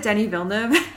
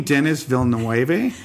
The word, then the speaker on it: Denis Villeneuve: Wait, do you mean Denis Villeneuve? – Because every time I hear, usually an American podcaster, try and pronounce his name. – Denis Villeneuve.